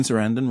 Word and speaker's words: Sarandon: Susan Sarandon rhymes with abandon.